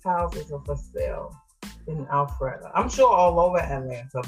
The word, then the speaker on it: Alpharetta: It's Alpharetta.